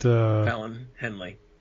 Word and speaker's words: Fallon Henley: Was it Fallon Henley? – Hey, you said it right.